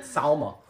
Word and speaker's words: Salma: Oh, I'm nookieing the shit out of Selma Hayek. – Okay. – And I'm marrying Gwen. – Okay. – Paltrow. – Marrying Gwyneth Paltrow. – Because she's a... But... Freaking Selma. Selma. How you say it? Salma.